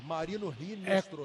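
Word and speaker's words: Marino Hinestroza: Se escreve com H, mas a pronúncia em espanhol é com R, Marino Rinestrosa.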